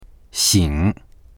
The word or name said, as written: xing3